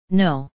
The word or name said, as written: no